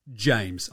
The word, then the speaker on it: Jaymz: So my name, James. I spell it J-A-Y-M-Z because I'm a moron.